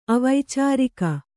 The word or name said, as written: ಅವೈಚಾರಿಕ